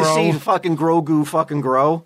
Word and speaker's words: Grogu: Yeah, Grogu.